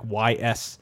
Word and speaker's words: Ys: And I don't really remember the details of that, but yeah, there's some pre-existing like YS, and I guess I should also point out that yes, the pronunciation of the game is Ys.